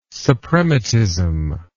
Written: suprematism

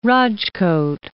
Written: Rajkot

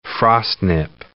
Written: frostnip

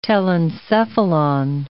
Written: telencephalon

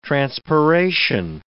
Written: transpiration

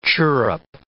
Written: chirrup

